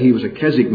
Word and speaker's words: Keswick: Perhaps you've heard that word, K-E-S-W-I-C-K. Most Americans pronounce that Keswick, but the W is silent, it's Keswick.